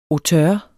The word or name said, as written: auteur